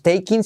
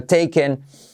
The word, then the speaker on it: taken: Y como E no es taking, sino taken.